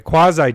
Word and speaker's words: Quasi: But it's Quadzai Duplicate. And that's one. I said it right. Quasi Duplicate. I said quad. Like your quads.